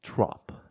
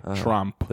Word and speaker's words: Tromp: Trump. Not Trump. – So it's basically, so it's like spelled like Trump, but instead of the U, it's an O? – Yeah. – Okay, Trump.